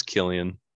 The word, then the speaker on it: Cillian: I don't think you pronounce the C, the K, or the C as a K, unless it's a K. I thought it was Cillian. All right, on the Wikipedia page. – Here we go. – Pronounced fucking with a K? Oh my god. I think it is Killian. It's just a different spelling of the Killian with the kid.